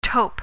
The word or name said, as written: tope